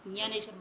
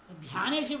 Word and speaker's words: ज्ञानेश्वर: और महाराष्ट्र में तो और भी ज्यादा गड़बड़ है हाँ ज्ञे बोल देते ज्ञानेश्वर महाराज ध्यानेश्वर बोल देते